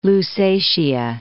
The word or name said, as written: Lusatia